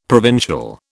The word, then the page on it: provincial: British English